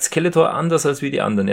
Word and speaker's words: Skeletor: Der HG Francis, der sagt nämlich immer Skeletor. Also er betont das erste E so, er betont die erste Silbe. Das erste E so ein bisschen länger, so ganz klassische deutsche Betonung.